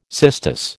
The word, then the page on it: cistus: American English